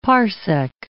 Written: parsec

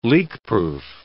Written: leakproof

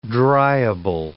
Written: dryable

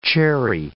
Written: cherry

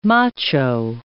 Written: macho

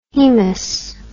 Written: haemus

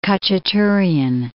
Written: Khachaturian

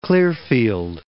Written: Clearfield

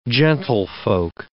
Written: gentlefolk

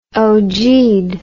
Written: ogeed

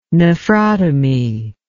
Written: nephrotomy

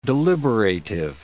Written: deliberative

